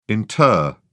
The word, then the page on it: inter: British English